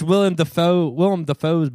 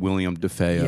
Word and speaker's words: Willem Dafoe: Usually it comes out William DeFeo.